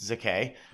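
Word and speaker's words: Székely: Eva Zeke, spelled S-Z-E-K-E-L-Y, but I believe it's pronounced Zeke, okay.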